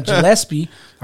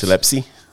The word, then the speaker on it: Gillespie: I always butcher his name. Gillespie. I always want to put the P before the S. – Gillespie.